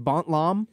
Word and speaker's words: Bontlam: I think Bont Lam. – Yeah, is that how you pronounce that? – Yeah, I mean, it's like, you know, it's open to interpretation. It was originally Bedlam. And now it's Bontlam. – Bontlam. Okay.